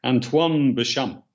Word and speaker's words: Antoine Béchamp: his name was Antoine Béchamp. I don't know if I'm pronouncing that correctly. – You did. That's the one.